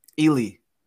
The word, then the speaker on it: Ealy: How do you pronounce it? – Ealy. – Ealy. – Yeah.